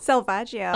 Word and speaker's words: Selvaggio: Selvaggio. – Oh, I did it. – Kind of rhymes with DiMaggio.